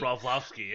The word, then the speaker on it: Broflovski: Broflovski? – Broflovski, yeah.